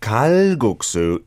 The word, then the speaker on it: kalguksu: Sherry Osborne saying, kalguksu, no, it's the kiok sound, so kal, like an almost K sound. And as Ryan was saying, phonetically, if you spell it K-A-L-G-U-K-S-A, you'll see lots of stuff about it, right?